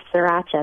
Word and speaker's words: sriracha: and then a tablespoon of sriracha sauce, is that how it's pronounced? – Yes, sriracha, that's right, it's just kind of a hot sauce.